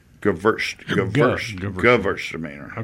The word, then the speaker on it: Gewürztraminer: Gewürztraminer. – Okay. – It's G-U-W, but they pronounce it verse, you know, because it's German, not English. – Absolutely. – Right. W's or V's.